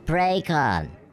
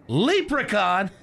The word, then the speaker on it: Precon: Lee Precon. Leprechaun? Your parents had a right cruel sense of humor, didn't they? – Not leprechaun, you jackass. – It's pronounced precon. It's French.